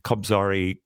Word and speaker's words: Kobzari: Kobzari. Am I saying that right? – Yeah, I think so, yeah. – Kobzari Chorus, the bards of Ukrainian history.